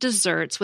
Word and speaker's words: deserts: Odd as it may be, the word desserts with one S in the middle and pronounced like the sweet treat has been used in English since the 13th century to mean things deserved.